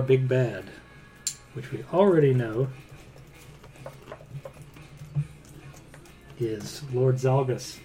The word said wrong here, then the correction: Zalbus: Oh, I'm sorry, Zalbus. Z-A-L-B-U-S.